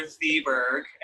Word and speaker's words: Thieberg: I'm Spencer Thieberg, and that is how you say my last name. – I've been saying it wrong for like 4 years now. – Yes, it's true. I am Spencer Thieberg.